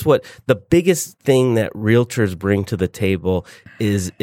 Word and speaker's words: Realtors: because I'm a representative of the Association of Realtors, Realtors, two syllables. – Tor. – Realtors. – Not realtor.